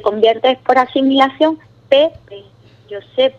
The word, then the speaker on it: Josep: donde dicen que José deriva de Josep, con PH al final. Entonces, en español es muy difícil pronunciar esa P al final.